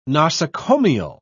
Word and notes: nosocomial: British English

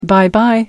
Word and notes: bye bye: British English

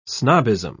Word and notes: snobbism: British English

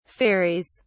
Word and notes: ferries: British English

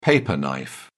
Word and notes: paper knife: British English

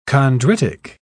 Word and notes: chondritic: British English

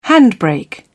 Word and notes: handbrake: British English

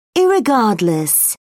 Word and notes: irregardless: British English

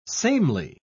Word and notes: samely: British English

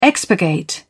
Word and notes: expurgate: British English

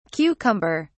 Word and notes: cucumber(s): British English

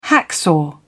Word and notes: hacksaw: British English

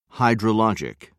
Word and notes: hydrology: American English

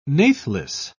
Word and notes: nathless: British English

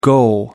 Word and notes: go up: American English

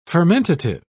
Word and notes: fermentative: British English

